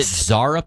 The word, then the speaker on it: Zara: Is it Zara pants? Have you ever heard of Z-A-R-A? Is that how you say it? – Yes.